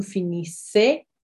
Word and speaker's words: finissez: And then vous, which is you, plural or and or formal, can be singular and plural, is finissez, so I-S-S-E-Z, so vous finissez. Vous finissez, our E-Z, our Z on the end there is silent as well. So vous finissez.